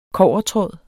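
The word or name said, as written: kobbertråd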